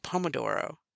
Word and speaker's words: Pomodoro: There's like a, I think it's called a Pompadoro. I'm probably saying it wrong. Pomodoro.